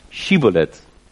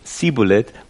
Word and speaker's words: shibboleth: Het goede uitspreken van shibboleth, dus shibboleth of sibboleth, maakte het verschil uit tussen leven en dood. Nou, verdrietig in dit verhaal is dat eigenlijk de Gileadieten, dat waren van de stam Manasse, en dan had je Ephraim, ze waren eigenlijk broers van elkaar, en die hadden ruzie met elkaar. Maar de Ephraimieten, op een of andere manier, konden de sh niet uitspreken. Ze zeiden sibboleth.